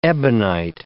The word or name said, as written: ebonite